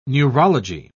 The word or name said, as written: neurology